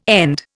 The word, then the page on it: end: Indian